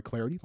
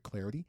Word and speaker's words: Clardy: By the way, you can follow me on Twitter too, at Troy Clarity. Last name is spelled C-L-A-R-D-Y, at Troy Clarity.